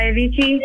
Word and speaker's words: Avicii: I'm sorry, the song Wake Me Up by Avicii. – Oh, I think, yeah. I'm saying his name right. – Yeah, that's it, from 11 years ago.